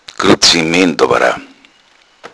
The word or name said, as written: gruzement